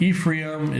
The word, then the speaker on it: Ephraim: Manasseh, you spell that M-A-N-A-S-S-E-H. Manasseh. And Ephraim is E-P-H-R-A-I-M. Ephraim.